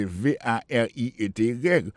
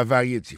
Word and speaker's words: variety: En anglais, il préécrit presque même genre, c'est V A R I E T Y. Vous avez quoi dit variété. C'est pas variété, prononciation c'est variety.